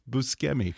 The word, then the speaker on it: Buscemi: I did that to Steve Buscemi just the other day as well, which apparently is supposed to be pronounced Buscemi. – Yeah, because it's different than in English. C. One C in Italian is a hard C, and that is not what we expect to find.